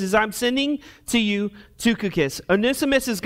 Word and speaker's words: Tychicus: So let's start with Tychicus. Now, it starts with a Y, but it's pronounced with a U. That's Tychicus.